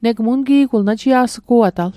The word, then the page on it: ungi'gul: un'ki'kul; Mi'gmaq